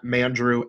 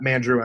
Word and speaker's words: Mandrew: At Mandrew NFL. Just Andrew with an M at the beginning. It's something my nieces and nephews called me before they could really say my name. So at Mandrew NFL Draft, and they can find my work over at The Score Crow and at Dynasty Happy Hour as well.